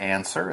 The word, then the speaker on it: answer: It's a word, power word, as you said, it's a verb.And it's spelled A-N-S-W-E-R, answer. Now you'll notice there is a W in the word answer, but we don't say it. So you say answer, not answer.